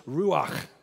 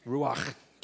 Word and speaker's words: ruach: The Old Testament word for Hebrew is ruach, and you have to do the Klingon thing at the end. Ruach, like Tim Mackey says, like you're going to hawk a loogie, right? Ruach. Anyways, that's really how they say it. Okay. Anyway, it's R-E-U-O-C-H, in case you want to write it down.